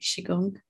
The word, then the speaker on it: Qigong: I managed to say it right, Qigong.